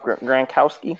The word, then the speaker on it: Gronkowski: Who's their freaking – Rob Gronkowski.